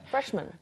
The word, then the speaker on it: freshman: 그런데 이게 한 단어가 되면서 mm-hmm. 그렇게 freshman이라고 하진 않아요. – No, freshman. – man처럼 발음합니다. freshman. – freshman.